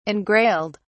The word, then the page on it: engrailed: British English